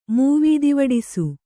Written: ಮೂವೀದಿವಡಿಸು

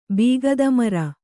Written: ಬೀಗದಮರ

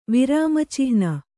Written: ವಿರಾಮಚಿಹ್ನೆ

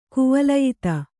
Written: ಕುವಲಯಿತ

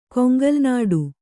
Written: ಕೊಂಗಲ್ನಾಡು

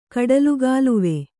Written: ಕಡಲುಗಾಲುವೆ

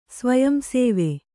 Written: ಸ್ವಯಂಸೇವೆ

